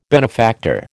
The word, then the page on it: benefactor: American English